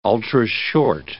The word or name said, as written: ultrashort